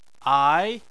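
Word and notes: ai: tone 2

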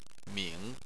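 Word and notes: ming3: tone 3

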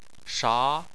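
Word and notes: sha: tone 2

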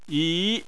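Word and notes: yi: tone 2

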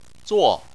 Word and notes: zuo: 4

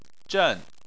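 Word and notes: zhen: tone 4

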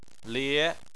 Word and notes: lie: 2

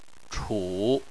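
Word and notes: chu: tone 3

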